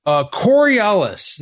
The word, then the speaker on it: Coriolis: Coriolis. – You mean Coriolis? – But Coriolis. – Whatever, like Coriolis, you know? Is like the Coriolis effect.